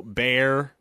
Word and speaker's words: Bexar: Refurio, is that the one? – Refurio, Bear. – How's it spelled? – B-E-X-A-R.